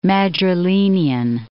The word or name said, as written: Madrilenian